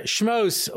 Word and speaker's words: Shemos: Anyway, so we're starting now a new Sefer Shemos, or if you have the Sephardi pronunciation, Sefer Shmot, because this is the book where God smote the Egyptians.